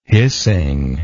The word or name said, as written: hissing